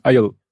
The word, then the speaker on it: Aisle: Äh, Aussprache von, jetzt das englische Wort, was den Gang im Flugzeug beschreibt. Gangway? Aisle. Die Insel? A, A, nee, Aisle geschrieben und Ach so. André sagt Isle.